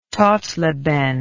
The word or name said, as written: Totleben